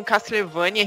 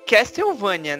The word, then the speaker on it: Castlevania: Que o Matheus tá falando um Castlevania, a gente falou esse nome por muito tempo, mas na verdade o, nome, a pronúncia certa seria Castlevania, né? – Castlevania, exato.